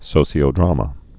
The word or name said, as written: sociodramas